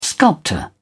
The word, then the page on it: sculptor: British English